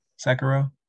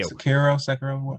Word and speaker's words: Sekiro: Sekiro. – Thank you. – Sekiro.